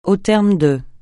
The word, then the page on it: au terme de: francais